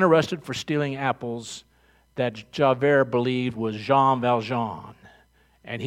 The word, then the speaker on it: Javert: Until one day, he was being pursued by this wonderful cop by the name of Javert. That's how you pronounce it. Say it with me, Javert.